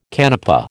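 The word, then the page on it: canapé: American English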